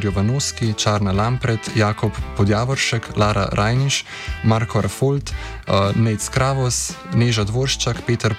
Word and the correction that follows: Rafold: Podgoršek, Jure Šimonka, Urban Brenčič, sem koga narobe izgovoril? – Ja, mislim, da je Rafold. – No. Marko Rafold, pardon.